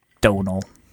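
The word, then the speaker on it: Domhnall: Donald Gleason. – Yeah. Liam Tasker says, "Hi, Tropers. Blank is pronounced the exact same as the name Donal."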